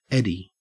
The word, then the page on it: eddy: Australian English